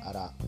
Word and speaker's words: ALA: アラ ALA と書いてアラ